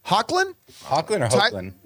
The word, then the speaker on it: Hoechlin: So when they announced that they were doing a Superman and Lois show, and it was still, I never know how to pronounce Tyler Hoechlin. – Hoechlin.